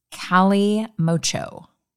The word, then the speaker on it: kalimotxo: And it's spelled K-A-L-I-M-O-T-X-O, pronounced Cali Mocho.